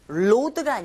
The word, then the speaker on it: road: 그리고 road가 아니라 road. R 사운드 시작할 때 여러분, 네, 잘 안 되시면 R 발음이 입모양을 우 하고 내밀어 보시고 시작하세요. road, road. 이렇게 하시면 충분히 따라 하실 수 있습니다.